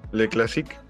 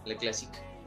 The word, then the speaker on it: Le Classique: clásica ¿O cómo se pronuncia? – Le Classique. Ah, sí, Le Classique.